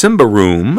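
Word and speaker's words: Symbaroum: First, a Swedish game called Simbaroom, pronounced Simbaroom. Hey, look at that! I got it right!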